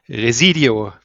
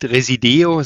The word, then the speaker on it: Resideo: Ja, du hast es schon angesprochen, Residio wird es ausgesprochen, nicht Resideo, sondern Residio. Das ist die richtige Betonung, ja.